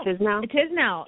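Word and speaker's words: Tiznow: Tisnow? Am I saying it right? – Tisnow. – Yeah.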